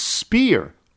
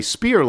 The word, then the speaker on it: Spear: As a kid, I was on the swim team at a local pool in Falls Church, and Robert Buddy, pronounced Spear. Oh, okay, Spear like a weapon, was the teenage star of the team and owner of almost half the team records.